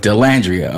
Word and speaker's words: Dallandria: Dallandria.